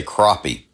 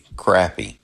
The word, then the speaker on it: crappie: Some people say crappie, which is closer to the original French pronunciation, crappé. Now that sounds like I'm making it up, but I'm not. But the word has evolved to be spelled with an A and two P's, so in most of the South, we say crappie.